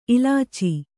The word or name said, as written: ಇಲಾಚಿ